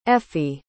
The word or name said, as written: effie